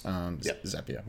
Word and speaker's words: Zapier: It is Zapier is the correct way to say it, according to the founder / CEO or whatever, because they're Zaps. You're creating Zaps. Zapier.